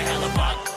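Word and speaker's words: Hellebuyck: That's Connor Hellebuck. Buck, buck. Yeah, Connor Hellebuck. Buck, buck. Yeah, Connor Hellebuck.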